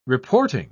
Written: reporting

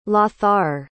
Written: Lothar

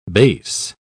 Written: base

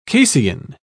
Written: casein

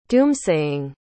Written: doomsaying

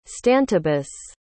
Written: stantibus

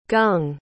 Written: gung

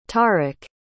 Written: tariq

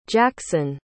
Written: jaxxon

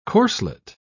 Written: corselet

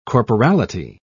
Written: corporality

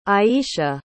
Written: aiesha

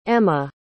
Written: emme

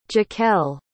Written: jacquelle

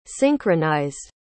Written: synchronized

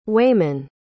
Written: waymon

